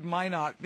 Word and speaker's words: Minot: And we are opening a new private course in Minot, North Dakota, about a month and a half. – I'm glad you said Minot, because I didn't know how to pronounce.